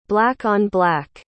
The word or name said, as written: black-on-black